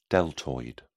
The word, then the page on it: deltoid: British English